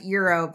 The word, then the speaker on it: Gyro: It's G-Y-R-O, and I always pronounce it gyro. It's like, no, his name's Gyro.